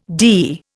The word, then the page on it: DE: Indian